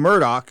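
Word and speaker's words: Murdaugh: They call him Murdoch. That's how he wants to pronounce his name.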